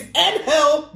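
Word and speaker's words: Ángel: I'll repeat this again for these people that don't know how to pronounce his first name, damn it. It's in hell Garza defending the title against Isaiah Swerve Scott of NXT.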